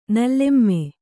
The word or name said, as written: ನೀಲಿಮೆ